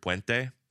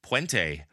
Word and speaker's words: puente: You know, I realized on Thursday when we were doing our Patreon bonus episode that I really need to work on my pronunciation of the word puente because I'm so Californian. My default is puente.